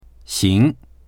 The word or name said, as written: xing2